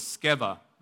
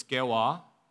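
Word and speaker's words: Sceva: And part two, I'm going to title it as Holy Handkerchiefs and the Son of, and the second word that's difficult is this word, I'm going to pronounce as Skeva, okay? The sons of Skeva. In the Greek, it's Skewa. Koreans pronounce it as Skewa, all right? Because they're smarter than most people. And we're just going to say Skeva, okay?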